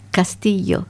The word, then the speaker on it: Castillo: Y yo tengo dificultad en pronunciar la EYE de dos L's. Uh-huh. No me sale. – ¿No sabes decir Castillo? – A ver, Castillo. – Sí, bien.